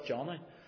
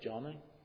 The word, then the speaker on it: Joni: You know, I never knew how to pronounce her name. I was always stuck as a Joni or Johnny, but on the video she calls herself Johnny, J-O-N-I. She calls herself twice, Johnny.